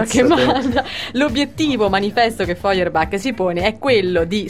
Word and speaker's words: Feuerbach: Io ho trovato questo testo di Feuerbach. – Sì. – Sì, se così si pronuncia, credo di sì. – Feuerbach.